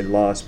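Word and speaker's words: Loess: So this term, it's actually German. It's probably pronounced Lurs, something along those lines. Most folks just say Lus, but it's primarily silt-sized material that has been deposited by the wind.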